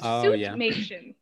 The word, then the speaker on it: suitmation: So it's actually suit as in a suit. – Suitmation. – Yeah, but it's they omit the E in the middle, so I kind of like goes forward.